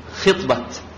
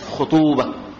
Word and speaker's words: خطبة: أنا سأذهب لخطوبة زيد، إيش الصواب؟ ها؟ خطبة، هكذا؟ بالخو ولا بالخي؟ خطبة، نحن نقول إيش؟ خطوبة، خطوبة، وهذا غلط.